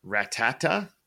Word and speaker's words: Rattata: Number 19, we have the Rattata. I said it right this time, people.